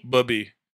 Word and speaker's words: Bubby: Bubby.